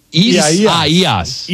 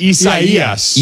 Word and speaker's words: Isaias: Is that, where are we at with the pronunciation on this? – Isaiisa. Isaisa? – Yaias. – No one knows. – Isaias. – Isaias. – Isaias.